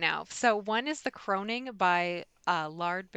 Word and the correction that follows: Laird: Baron, if I'm saying his name right. It's my first time reading. – Laird. – Thank you.